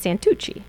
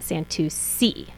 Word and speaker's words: Santucci: If you want to be fancy and you want to say my name in Italian, then it's Santucci. – Okay. – But if you want to say it in English, like my husband's family when they came to America, then they pronounce it Santucci.